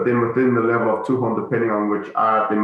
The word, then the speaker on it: Tuhon: Tuhan.